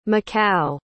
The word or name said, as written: macau